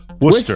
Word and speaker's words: Worcester: A Worcester, Massachusetts man, and by the way, that's how you say it, Worcester. – Isn't it spelled Worcester? It's Worcester. – Worcester. – And if you're from the area, Worcester. – That's a tricky one. – A Worcester, Massachusetts man had surgery to remove an AirPod from his esophagus last Tuesday after he swallowed the device when he fell.